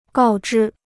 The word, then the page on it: 告知: gào zhī